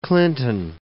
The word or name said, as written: Clinton